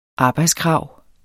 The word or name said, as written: arbejdskrav